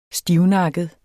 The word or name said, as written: stivnakket